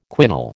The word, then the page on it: quinol: American English